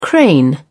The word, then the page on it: crane: British English